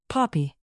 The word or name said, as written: poppy